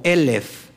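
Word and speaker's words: Elef (אלף): Pero aquí llama la atención que el número mil igual se escribe Aleph, solamente que se pronuncia diferente. Se pronuncia como Elef y Elef tiene un valor de mil.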